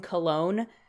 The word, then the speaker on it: Colon: But unfortunately for him, his name is Benjamin Cologne. It's spelled colon, but pronounced Cologne.